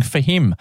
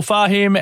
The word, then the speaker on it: Fahim: I've been saying for him. I've been putting the wrong emphasis on the wrong syllable. Rachel Fahim, our artist of the week this week.